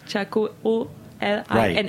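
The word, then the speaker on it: Txakolina: Appellation. Spell it for me. – T-X-A. – Oh, okay. – Chacolina is Basque.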